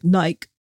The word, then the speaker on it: Nike: I have opinions on a lot of things with pronunciations based on where you live, how we, where the company was founded, say Nike, but you go over to the UK and they're like Nike. It's like, no, that's not how you say it.